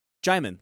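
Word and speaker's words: Djimon: It's D-J-I-M-O-N is how you say his first name. – Hmm. – And whenever he talked to Russell Crowe, he asked him, like, how do you pronounce his name? It's like, Jaimon, like Simon.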